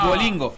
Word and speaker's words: Duolingo: Dublingo. Bien. Dublingo dublingo ah. Duolingo. Duolingo, sí, es bueno Duolingo.